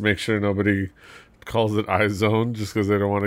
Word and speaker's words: IZ*ONE: The ladies of IZ*ONE, yes, that's how you pronounce it, IZ*ONE. What was it? IZ*ONE. It's not IZ*ONE.